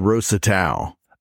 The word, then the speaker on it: Rosetau: It's called Rosetau. – I've always thought that name was interesting. Rosetau. It's spelled R-O-S-E, Rose, and Tau, T-A-U, Tau as in cross.